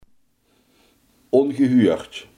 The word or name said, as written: ongehoord